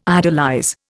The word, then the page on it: idolize: Indian